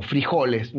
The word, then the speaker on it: frijoles: El Bonanza es un juego de negociación, donde tú tienes que negociar, y acá te vas a reír, porotos o frijoles, no sé cómo les dicen en Colombia. – Frijoles. Nosotros le ponemos acento en la I, los mexicanos en la O.